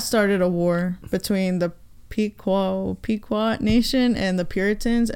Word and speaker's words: Pequot: It is indeed pronounced as Pequot War, and it's not the same thing as the King Philip's War, which happened later on.